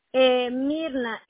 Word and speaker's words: Mirna: Mirna, M-I-R-N-A.